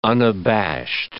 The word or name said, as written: unabashed